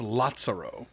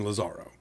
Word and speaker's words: Lazzaro: We've really got to I do want to thank all the people who told us that we were pronouncing Felice correctly, but it's Lazzaro. – Lazzaro Felice. – Lazzaro Felice. We were saying Lazaro.